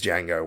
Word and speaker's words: Django: He says the D in silent. D is silent. He goes, I know. It's a reference to the fact that he was Django once.